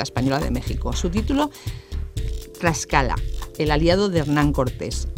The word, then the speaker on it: Tlaxcala: Y ya has visto que, bueno, me ha resultado un poco difícil decir Tlaxcala. – Bueno, es complicado porque la TL pues no la pronunciamos en España, pero en México se utiliza mucho y bueno, pues así es como se llama, Tlaxcala.